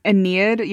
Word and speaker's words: Aeneid: The Aeneid? – Aeneid, yeah.